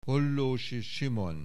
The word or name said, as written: Hollósy, Simon